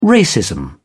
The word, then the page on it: racism: British English